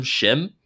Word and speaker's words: SCHiM: Shim.